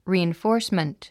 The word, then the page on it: reinforcement: rìːinfɔ́ːrsmənt; リーインフォースメント